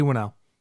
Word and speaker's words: Whannell: Uh, Leigh Whannell. Wan Whannell. – Yeah, you were right. He, Lee, it's Leigh Whannell.